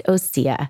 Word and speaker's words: Osea: It's pronounced Osea,